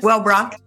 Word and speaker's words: Wellbrook: Wellbrook.